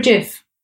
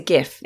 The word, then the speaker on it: GIF: Is it a GIF or a JIF? – Oh, always a GIF. – It's always a G. – GIF. Like the word gift.